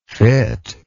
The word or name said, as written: fittable